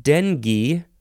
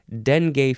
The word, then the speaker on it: dengue: The next word is dengue or dengue. I've always heard it pronounced dengue, but I guess dengue is fine too. It's fine. This is spelled D-E-N-G-U-E, noun from 1828.